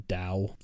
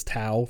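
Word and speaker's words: Dao (道): I can't remember the exact line, but it's in standard Mandarin, it's actually pronounced Dao. There is a pronunciation that is Tao for real, but most people, I believe, would call it Dao, but I don't know.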